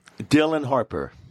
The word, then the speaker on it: Dylan Harper: What is her name? Dillian Harper? – Dylan Harper. – So you don't pronounce the I? – I don't know.